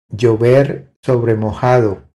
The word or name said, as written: Llover sobre mojado